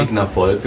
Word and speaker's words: Rigna Folk: Die einen sagen Rigna Folk, die anderen Rigna Folk. – Wie, sag mal. Rigna Folk, wie man es liest.